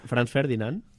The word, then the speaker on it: Franz Ferdinand: Franz Ferdinand. Sí, sí, ya lo he dicho bien.